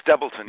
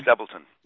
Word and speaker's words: Stebleton: Stebleton, yes, Stebleton.